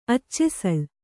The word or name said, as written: ಅಚ್ಚೆಸಳು